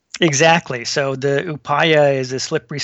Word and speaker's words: Upaya: Yeah, Upaya, yeah. – Upaya, yeah.